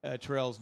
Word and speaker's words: Terrell: But you're saying Terrell's name wrong. It rhymes with Cheryl. Terrell and Cheryl.